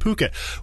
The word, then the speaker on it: Phuket: It does have the PH at the beginning. Don't let that fool you. It is pronounced Phuket.